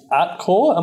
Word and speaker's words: Atkore: Uh, the next stock uh, that you are having a look at is Atkore. Am I saying that right? – Yeah, hundred percent. – A T K O R E.